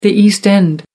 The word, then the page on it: east end: American English